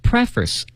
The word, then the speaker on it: preface: 朋友们，这个词啊看着好像挺简单的 ,pre, 加上一个脸部的这个 face, 是吧？ – 嗯。 – 但它发音不一样，它叫读成 preface。